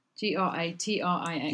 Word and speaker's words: Gratrix: I think it's Gratrix or Gratrix. Gratrix. How would you say that? G R A T R I X.